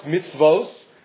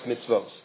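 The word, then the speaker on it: mitzvos (מצות): Now, the word is pronounced mitzvos. It is written mitzvahs. It's one vav. It is written mem tzadi vav tav, but it's pronounced mitzvos.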